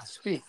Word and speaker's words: Spieth: Yeah, Spieth.